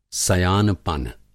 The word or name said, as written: सयानपन